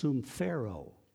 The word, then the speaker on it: sumphero: Because in the Greek language, which is what the New Testament was written in, the word for common is pronounced sumphero. S-U-M-P-H-E-R-O.